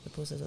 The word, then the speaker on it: pupusas: And I love pupusas. Am I saying that right? – Pupusas, yeah.